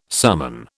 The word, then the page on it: summon: British English